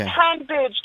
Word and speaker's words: Hanbridge: And it's Hanbridge, there's no R in that name.